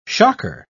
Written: shocker

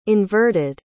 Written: inverted

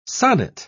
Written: sonnet

